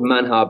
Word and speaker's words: manjar: Manja is spelt with a J in the middle, so it's spelt manjar, but of course in Spanish it's pronounced manja.